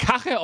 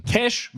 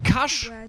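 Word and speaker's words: Cache: Das wird nicht Kache ausgesprochen. Diese Aussprache ist auch nicht korrekt. Es gibt genau zwei Varianten, es im Deutschen richtig auszusprechen. Cash, wie das englische Wort für Geld, und Cash ohne E am Ende.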